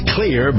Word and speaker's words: Xlear: It's pronounced clear, but it's spelled X-L-E-A-R.